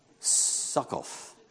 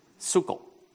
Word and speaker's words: Sukkot: Festival of Booths. Succoth. Sukkot. Yeah, Sukkot if you talk like that.